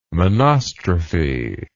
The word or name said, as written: monostrophe